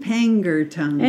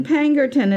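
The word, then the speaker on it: Pangnirtung: in Pangnirktung. Am I saying that right? – It's in Pangnirktung. – In Pangnirktung, in Nunavut.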